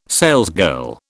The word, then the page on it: salesgirl: British English